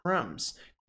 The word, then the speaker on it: crumbs: Crumbs. Crumbs. Crumbs. Crumbs. Crumbs. Cuidado, não é crumbs, esse B não soa, então é crumbs, crumbs, crumbs.